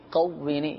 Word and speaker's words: قوینی: یہاں یہ عرض کرتا چلوں مدنی چینل کے ناظرین، جب یہ وظیفہ پڑھیں تو اس کا جو تلفظ ہے قوینی، اس میں موٹا قوف ہے، یا اللہ قوینی، موٹا قوف، ایک ہوتا ہے باریک قاف، ایک ہوتا ہے موٹا قوف، تو اس میں موٹا قوف ہے.